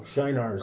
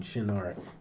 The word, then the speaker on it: Shinar: Now, Shinar is the English pronunciation. Shinar is correct. It's Hebrew.